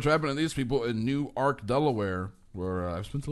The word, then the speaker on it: Newark: New Ark. – Oh, wow. – Newark is in New Jersey. New Ark, spelled the same, is in Delaware.